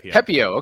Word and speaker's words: Pepiot: Pepiot.